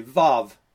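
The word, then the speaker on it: vav (ו): And the transition word in Hebrew is it's pronounced this way, vav, and it's really a word for but.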